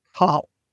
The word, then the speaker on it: Cathal: So his name is Cathal, which is spelled C-A-T-H-A-L, but the T is silent, and that trips everyone up.